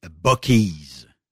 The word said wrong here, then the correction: Buckeyes: Des Buckeyes. Buckeyes, Buckeyes. – c'est ouais. – la, prononciation est Buckeyes.